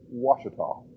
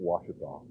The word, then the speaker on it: Ouachita: In Arkansas, they spell it with a W. We chuckle about this a little bit, but I do it intentionally. I hope you will hear very carefully. It is pronounced Ouachita, so that when the gentleman who is the president of that institution comes here to be one of the evaluators on our campus, that you will pronounce it Ouachita.